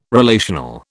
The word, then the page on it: relational: British English